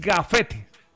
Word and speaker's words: gafete: No, porque no es grafetes. – Ah, ok. – Es gafete. – Válido. No lo entendía, no lo pronuncié bien, tiene toda la razón.